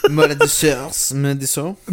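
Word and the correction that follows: Maredsous: I was saying it, you know, Americanized. Okay. Maritsu. – Sounds like that.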